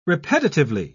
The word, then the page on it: repetitively: British English